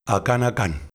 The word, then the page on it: Akanakan: LHHL